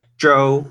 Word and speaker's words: Zhou: Yes, but the correct Chinese pronunciation is Zhou, which I think Zhou more accurately encapsulates.